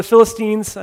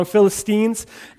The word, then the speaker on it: Philistines: And so there's the city where the Philistines were, the Philistines, I keep saying that wrong, Philistines, and they were kind of enemies of the people of Israel, obviously.